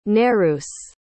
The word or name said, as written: nereus